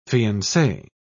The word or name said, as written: fiancee